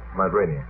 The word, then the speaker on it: Rainier: Rainier. How do you pronounce that in America? Mount Rainier. Good.